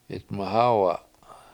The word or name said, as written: 7itmahAwa7